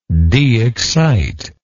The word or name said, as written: deexcitation